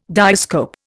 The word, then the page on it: diascope: Indian